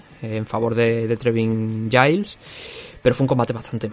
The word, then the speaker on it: Giles: Trevin Giles. Me he enterado que se pronuncia Giles.